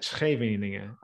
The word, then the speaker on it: Scheveningen: but it's this place called Scheveningen. Exactly. Scheveningen.